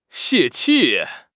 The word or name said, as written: 泄气